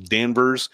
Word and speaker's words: Danvers: I went to school in Danvers, which most of you would pronounce Danvers, which is actually Salem Village, which is where the witches lived.